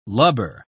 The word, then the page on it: lubber: British English